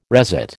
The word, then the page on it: resit: American English